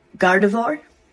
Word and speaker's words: Gardevoir: That is a Gardevoir, a Venusaur, Incineroar, a Dreadnought, and Eldegoss.